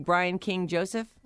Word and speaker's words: Brian King Joseph: So last year, we were introduced to Brian King Joseph.